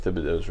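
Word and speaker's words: Thibodeaux: Thibodeau. Did I say that right? Thibodeau? – Yeah, from Oregon. Yeah. – Thibodeau? – I think Thibodeau's right.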